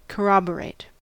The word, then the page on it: corroborate: American English